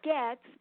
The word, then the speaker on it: Goetz: It's pronounced Goetz, but it's spelled G-O-E-T-Z.